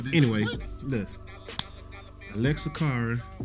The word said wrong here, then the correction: Alessia Cara: or Ella May? – Alessia Cara. – Yeah, I said it wrong, right? Alessia Cara.